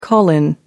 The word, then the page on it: call in: American English